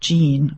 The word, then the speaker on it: djinn: I should have told you that D-J-I-N-N is pronounced Jean, which is where we get genie, magical beings.